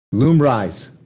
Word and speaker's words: Lumryz: And your drug's called, how do you pronounce it? Lumriz? Lumryz. Lumryz.